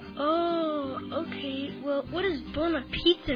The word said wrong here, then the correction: buonapazzo: It's buonapazzo. It means crazy good. You're not saying it right. Buonapazzo. You try it.